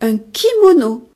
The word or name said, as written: kimono